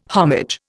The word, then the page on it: homage: Indian